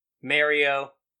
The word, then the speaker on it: Mario: Because I pronounce it the Italian way. – Mario.